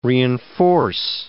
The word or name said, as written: reenforce